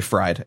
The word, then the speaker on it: Fried: No, it's actually Fried, F-R-I-E. – Friedman? – Oh, okay, cool. – Yeah, it's German. You pronounce the second letter.